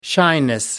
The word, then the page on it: shyness: American English